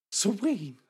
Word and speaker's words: Samhain: Soheen.